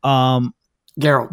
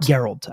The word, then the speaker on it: Geralt: Was it Geralt? I always get his name wrong. – Geralt. – Geralt.